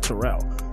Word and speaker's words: Terrell: But then again, the biggest person with my name was was Terrell Owens, but he says Terrell, and I pronounce it Terrell. So people would all, especially if they're from the South, would just call me Terrell all the time, and I'm like, that's not how you say my name.